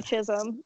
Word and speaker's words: Chisholm: Okay, my name is Katie Chisholm, and no one can pronounce my last name, so I'm not surprised.